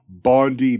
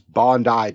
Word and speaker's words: Bondi: Oh, and speaking of lost in translations, in my last report, you called it Bondi Beach. But the correct way to pronounce it is Bondi Beach. Bondi. As in...